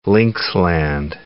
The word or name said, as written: linksland